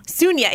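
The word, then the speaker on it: Suniya: Sunya, yeah. It's spelled Sunaya, and so I always want to say that, but Sunya Luthar, and she studies vulnerability and resilience.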